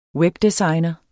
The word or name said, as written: webdesigner